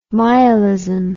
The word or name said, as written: myalism